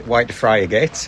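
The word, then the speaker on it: Whitefriargate: As our visitors correctly pronounce it, Whitefriggate. That's Whitefriggate from Hull.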